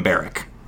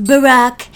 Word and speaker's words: Barack: I think you need to distance yourself from Barrack. – It's pronounced Barack, and I couldn't possibly distance myself anymore.